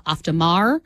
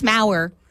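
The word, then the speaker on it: Auf der Maur: I have been pronouncing her name wrong this entire time because I always said Melissa Oftermauer. And then I listened to her audiobook, and I think she knows how to say her own name. It's Mauer. Rhymes with hour.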